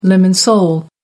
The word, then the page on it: lemon sole: American English